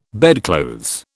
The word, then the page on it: bedclothes: British English